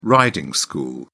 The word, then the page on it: riding school: British English